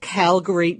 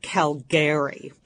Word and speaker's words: Calgary: We went to Calgary, Canada, which I will tell you right now is pronounced Calgary, not Calgary. That's a terrible faux pas.